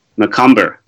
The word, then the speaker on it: Macomber: Although I'd just like to note, I think you mispronounced Seth's name. He takes great offense to that. Macomber.